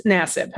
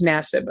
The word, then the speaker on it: Nassib: Now, in the video that the Raiders defensive end Carl Nassib, and I think it's Nassib. I said Nassib before, but I think it's Nassib when pronounced correctly.